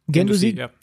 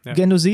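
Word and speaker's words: Guendouzi: Haben Sie einen der spektakulärsten Spieler verpflichtet mit dem Gendouzi? Also ich habe auf den geachtet. Wird, wird er überhaupt so ausgesprochen? – Gendusi, Gendusi, ja, ja.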